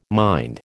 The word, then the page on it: mind: American English